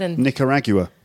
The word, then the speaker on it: Nicaragua: Is that how you say it? – Nicaragua. Yeah.